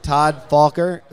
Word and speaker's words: Todd Falker: And if you forgot before, we've got Todd Falker, who is, am I saying that right? I think I am. – You are. Yeah. Nailed it. – Todd Falker from Puma, and he is the product line manager.